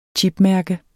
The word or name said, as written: chipmærke